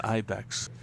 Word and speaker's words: Ibex: So, continuing on your offerings with products, I see that you have the Evonix Ibex available, or Ebex, how would you pronounce that? – I would say it's Ibex.